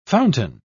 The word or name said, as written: fountain